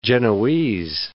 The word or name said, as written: Genoese